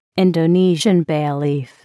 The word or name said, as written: Indonesian bay leaf